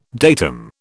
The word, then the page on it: datum: British English